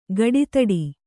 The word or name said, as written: ಕುಡುದಡಿ